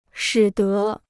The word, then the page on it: 使得: shǐ de